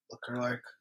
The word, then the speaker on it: Leclerc: Jose Leclerc. – Leclerc. – Yeah. Leclerc. That's definitely. – That, yeah, that's good.